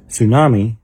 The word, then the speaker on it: tsunami: In the word tsunami, the T is silent. In the word wife, the husband is silent.